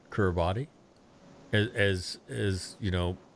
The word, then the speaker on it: Kiribati: It, it's Kiribati. I've heard different pronunciations, and I would say Kiribati because that's the first when I first heard about. That's how it was. I heard it pronounced, but uh, it's it's something like that, Kiribati.